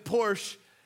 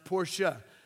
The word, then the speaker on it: Porsche: In fact, I called them Porsche until this week. I learned that that's grammatically incorrect and offensive to the Germans. It's pronounced Porsche. So, in case you're having an argument in your own house of how this is pronounced, it's technically Porsche.